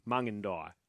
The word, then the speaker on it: Mungindai: Mungindai, sorry. I pronounced it wrong. I think I said Mungindi. Mungindai. Apologies. Thank you for the phonetics on that one.